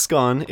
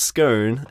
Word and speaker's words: Scone: She was born in 1847 to labourers on a pastoral station in the Upper Hunter Valley near a small town called Scone, which you certainly wouldn't want to mispronounce in your teenage years. Your friends would never let you forget it. So it wasn't Scone, it was Scone, and in this town of Scone, she was born.